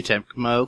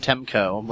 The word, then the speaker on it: Tecmo: R No Surge is going to be coming to the U.S., according to Tecmo Koei. I always want to call them Temco, like Namco.